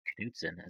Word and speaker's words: Knutson: Can I'm curious because I know that you're doing some work with Ted Knutson, as he likes to call himself in his podcast. – Because like, that is his name. – Is it pronounced Knutson? – Yes.